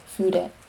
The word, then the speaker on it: Fude: Fude Yamaguchi, pronounced Fude, 2 syllables, is a close friend and ex-colleague in Tokyo.